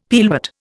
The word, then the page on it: pearlwort: Indian